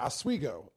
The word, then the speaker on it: Oswego: Yeah, Oswego. Okay. That's how a New Yorker would say it. A Minnesotan might say Oswego.